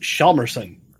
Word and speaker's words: Hjalmarsson: All right, I give half credit to the both of them, but the last name is actually pronounced Hjalmarsson.